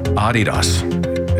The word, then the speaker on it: Adidas: It's not pronounced Adidas. Mm-hmm. We're the only people in the world that pronounce it Adidas. – Okay. – Adidas is how to say it. Adidas. Because the guy who found it is a German guy named Adi Dassler. Mm-hmm. And he named it after himself.